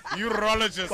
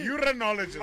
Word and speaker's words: urologist: Yes, urologist. – I had to check. Urologist. – What did I say? – Urinologist.